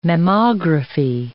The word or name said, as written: mammography